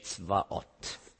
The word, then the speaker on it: Zebaoth: Zebaoth ist hebräisch Zvaoth. So ein bisschen deutsch ausgesprochen.